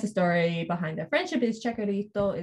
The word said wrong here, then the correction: Chicharito: is also from... Am I pronouncing that right? – I think Chicharito. It's Chicharito.